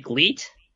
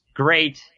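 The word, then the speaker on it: GLEAT: Isn't it Gleet? No, it's Great.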